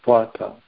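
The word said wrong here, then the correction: tvattaḥ: from you, tvataha, I mispronounced that, tvataha.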